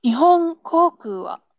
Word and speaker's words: 日本航空: You pronounce separately. Like you can split. Like Nihon Okay. especially the last word, last vowel, koku. This one, this word, try to um pronounce clearly. Okay, Nihon 日本航空は。